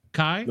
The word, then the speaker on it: Chi: Is it Kai McBride or Chi McBride? Kai. – Nobody. C H I. I don't even know who that is. No one. No you don't know Kai McBride? – you'll know.